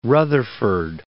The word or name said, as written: Rutherford